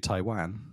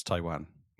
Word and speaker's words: Taiwan: How do you pronounce Taiwan? – Taiwan. – That's what I said. – You say Taiwan. – Taiwan. – Taiwan. – Taiwan. There's no R in it. – No R. No, Taiwan.